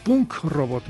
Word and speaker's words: Punk Robot: Punk Robot, me hace falta pronunciar bien la K.